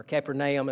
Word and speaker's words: Capernaum: sick at Capernaum, or Capernaum, as some might pronounce that.